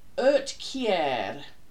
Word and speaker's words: Uhtceare: Uhtceare. U H T C E A R E. Oot, I would think, is oot. U H T, oot.